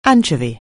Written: anchorwoman

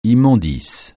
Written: immondices

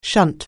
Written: shunt

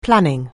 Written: planning

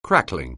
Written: crackling

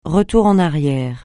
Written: retour en arrière

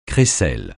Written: crécelle

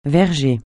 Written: verger

